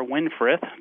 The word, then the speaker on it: Winfrith: His name was Winfred or Winfrith, depending on how you want to pronounce it.